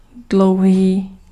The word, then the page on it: long: /ˈlɔŋ/